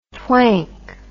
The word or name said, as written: twank